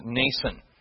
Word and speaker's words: Mnason: It's spelled with M-N, but we just pronounce it, the M is silent, you just pronounce it Mnason.